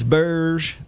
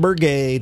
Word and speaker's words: Birge: It's Birge, B-I-R-G-E, not Bergay, not Berg, Birge, George Birge.